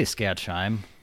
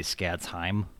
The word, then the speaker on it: Skadsheim: I say Skadsheim, but I've heard it should be Skadsheim. Like you're supposed to emphasize the kind of thing, yeah.